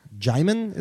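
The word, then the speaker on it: Djimon: Jimon is how you say it? – Yeah, Jimon Hansu from Blood Diamond.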